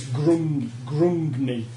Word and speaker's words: Grungni: Grugni. Lovely. Oh, it's Grungni. I can never say it right.